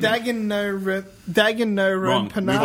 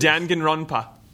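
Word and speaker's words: Danganronpa: Dangen Runpa. – That's... Yep, I'll take that.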